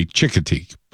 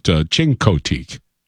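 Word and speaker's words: Chincoteague: He is what most of us say Chickateek, but Chincoteek is really a Chincoteek. Do you know? – Yeah, I mean... – The way it's spelled.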